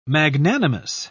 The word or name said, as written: magnanimous